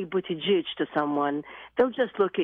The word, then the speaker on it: Buttigieg: But it's pronounced Buttigieg, and this poor man thinks his name is Buttigieg. So, what happened there? – In fairness, in Maltese, it's pronounced Buttigieg.